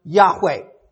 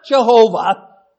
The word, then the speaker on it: Yahweh: Now, the HCSB, they have determined, as most have today, that it probably should be pronounced Yahweh. Yahweh, not Jehovah, Yahweh.